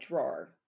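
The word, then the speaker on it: Dror: So how would you say his first name? I say it like drawer.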